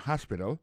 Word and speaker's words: hospital: L'hôpital, c'est comme ça, on dit ça en anglais, hospital. Alors, accent tombe sur premier syllabe, il y a trois syllabes. She likes to visit hospital. Ensuite, elle a écrit H-O-S-P-I-T-A-L, hospital, l'hôpital.